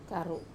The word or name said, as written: karu